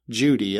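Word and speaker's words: Cudi: Christians, Jews, Muslims, and a lot of their ancient literature would point to a mountain called Mount Judi. It looks like Mount Cootie, but it's pronounced Mount Judi. J-U-D-I is the way it kind of looks if we were to pronounce it that way, but it's C-U-D-I.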